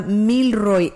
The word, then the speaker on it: Milroy: Milroy, como haga de cuenta como de mil, el número mil, y después Roy, R-O-Y, sí. Pero es una sola palabra, Milroy, M-I-L-R-O-Y, Lane, en Houston, Texas, 77066.